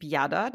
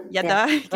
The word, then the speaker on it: Yada: Ist das Prinzip Yada, das habe ich jetzt richtig ausgesprochen. Yada, genau. Und da geht es eben um so den jüdischen Blick auf Sexualität.